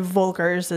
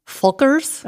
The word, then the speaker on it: Völkers: But it's for me to say Völkers is correct. – Totally acceptable. – What's right? – Völkers. – Oh, that's... – See? Engel and Völkers.